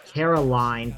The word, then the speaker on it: Caroline: Is it Carolyn Manzo? – Caroline.